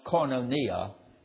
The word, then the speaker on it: koinonia: Now, the Greek word for fellowship, it's a Greek word pronounced koinonia.